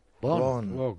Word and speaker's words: Vaughan: Se escribe Baugan y se pronuncia Bon.